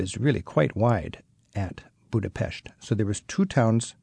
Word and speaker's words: Budapest: first of all, is it Budapest or Budapest? – It's Budapest. – So typically Western people say Budapest. – Yes, but the Hungarian way of pronunciation is Budapest.